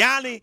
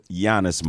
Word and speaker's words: Giannis: She'd be like Yanni, Yana, Yana. What? What's his name? – Giannis, mom.